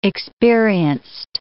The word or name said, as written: experienced